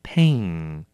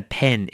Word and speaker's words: pain: 好，那要小心了， pain 这个字它的发音一定要念成 pain, 你一定要把 a 拉长，不然念起来就很像是笔。所以说我有，比如说我背痛， I've got a pain in my back, 不要念成 I've got a pen in my back, 我的背上有一支笔，跟我的背很痛，那就差很多了。